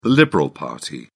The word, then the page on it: liberal party: British English